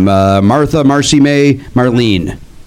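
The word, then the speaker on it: Martha Marcy May Marlene: And I saw, I get this name wrong each time, Martha, Marcy May, Marlene. Saw that. – I think you actually said it right, too.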